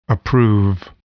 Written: approve

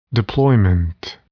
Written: deployment

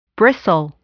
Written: bristle

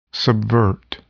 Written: subvert

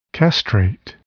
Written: castrate

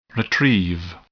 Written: retrieve

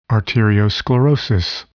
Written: arteriosclerosis

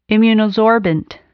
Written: immunosorbent

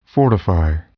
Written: fortify

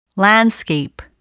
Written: landscape

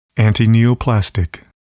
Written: antineoplastic

